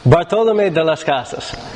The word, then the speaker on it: Bartolomé de las Casas: Bartolomé de las Casas.